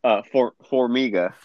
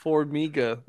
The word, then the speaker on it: Formiga: Formiga. – Formiga, which is not spelled like that at all, versus Benavidez for their second fight.